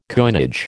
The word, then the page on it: coinage: American English